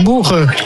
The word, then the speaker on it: bulgur: Ja, bulgur. Ja. – Ja. Dat is een ingewikkelde, want hoe spreek je het nou uit? – Ja, het is boel en dan geur.